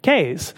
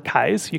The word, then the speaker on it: Qays: And once upon a time, long ago, there was this man named Qays, or Qays, you can pronounce it that way. It's Q-A-Y-S for those who are wondering.